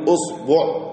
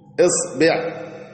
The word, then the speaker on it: إصبع: وإصبع فيها كم لغة؟ فيها تسع. إزاي؟ حيثما نطقت بها فهي صحيحة. اضرب ثلاثة في ثلاثة اللي هي الضمة والفتحة والكسرة على الثلاثة الأحرف الأولى، يعني نقول أصبع، إصبع، أصبع، وقلب الثلاثة ضربهم في بعض يأتيك كله صحيح.